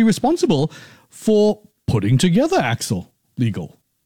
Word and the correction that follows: AIX Legal: Yeah, this program, AIX Legal. – Oh, AIX Legal.